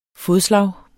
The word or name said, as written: FODSLAGET